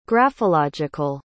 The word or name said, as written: graphological